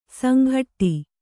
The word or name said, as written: ಸಂಘಟ್ಟಿ